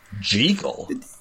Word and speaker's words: Jekyll: Jekyll? – I think technically that's how it's supposed to be pronounced.